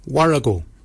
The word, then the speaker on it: worragol: Barken is spelled B-A-G-I-N-Y. Barken. Barken is your beard. The upper mouth, we say warrigal. Warrigal. Warrigal is spelled W-O-R-R-A-G-O-L.